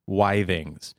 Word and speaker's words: Withings: I think it's pronounced Withings. It's spelled with ings, like Withings. – Okay. – But I think I believe it is pronounced Withings. – Withings. – Because any other pronunciation sounds stupid.